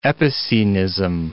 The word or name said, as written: epicenism